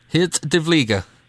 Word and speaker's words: Geert de Vlieger: If you have to pronounce it in Dutch, and then it's Geert de Vlieger. – Geert de Vlieger.